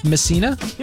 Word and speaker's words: Messina: Is that how you pronounce it? Messina? – Yeah.